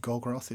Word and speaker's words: Gorgoroth: but Body Warping of Gorgoroth, is it? – Yes.